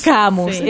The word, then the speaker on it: Camus: Camus, sí.